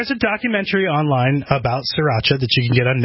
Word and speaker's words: Sriracha: Sriracha. That's how David Tran pronounces it, and I trust David Tran's judgment.